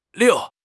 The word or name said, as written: 溜